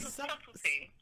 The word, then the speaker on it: subtlety: And your turn-ons, and maybe I'm just being stupid and drunk, but like your turn-ons are sublets. Now, what is that? Am I saying that wrong? Wait, what is that? – Subtlety. Did I spell it wrong? – Subtlety. All right.